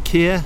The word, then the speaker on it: Keir: But I've got a bit of a weird name, Keir. Yep. It's difficult to spell. – How do you spell it? – K-E-I-R.